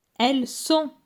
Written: Elles sont

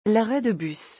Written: l'arrêt de bus

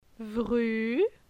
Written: Vru

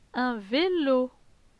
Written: Un velo

